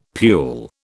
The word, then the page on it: pule: British English